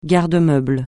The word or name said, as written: garde-manger